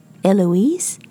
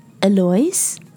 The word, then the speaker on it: Eloise: 讲出了几种不同的发音 Eloise, Eloise, or the French one, Eloise. Eloise 是法式的发音，和德式的发音又不一样 Eloise. 那当他讲到 Elise 的时候，我说了一句 But that's a different name. It's another name. 的确，在美国 Elise 是一个完全不一样的名字 E-L-I-S-E Elise Elise 和 Eloise 中间只差了一个字母，是字母 O。